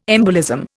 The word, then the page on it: embolism: Indian